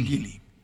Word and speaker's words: Geely: ¿Es Gili o Gili? – Gili. – Gili. – ¿Cómo se escribe? – Okay. G-E-E-L-Y.